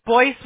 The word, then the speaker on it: Boyce: Because my name, Sean Boyce, is spelt in red. It's spelt B-O-Y-C-E, not B-O-Y-C-E. – Is Boyce with a B? – Yeah, not Boyce.